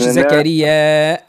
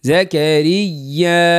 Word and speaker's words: زكريا: لهنا لهنا لهنا نمدوها الياء وننطق الهمزة. ذكر رحمة ربك عبده زكريا. قول. – ذكر رحمة ربك عبده زكريا. – لا مش زكريا. علاش هذيك؟ زكريا.